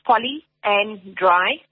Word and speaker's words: polyandry: So it's poly and dry. So if you pronounce it like that, if you break it up, mm-hmm.